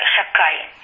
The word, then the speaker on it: Шакай: Это сторона управления Кадуш Баруху, которая называется Шедай. Мы не можем это имя произнести в цельности, поэтому произносим его как Шакай.